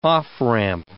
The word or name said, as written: off-ramp